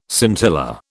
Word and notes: scintilla: British English

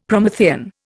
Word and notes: Promethean: Indian

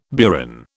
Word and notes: burin: British English

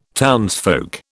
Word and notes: townsfolk: British English

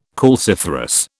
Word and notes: calciferous: British English

